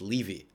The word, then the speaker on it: Levy: So my name is a last name and it's pronounced Levy. I don't know why.